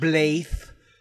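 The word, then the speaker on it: Blaidd: Apparently, it's Welsh and it's pronounced Blaith, but it's B L A I D D.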